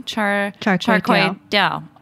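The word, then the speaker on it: char kway teow: So how do I pronounce that actually? – Char kway dao. – Mm-hmm. Dao, okay.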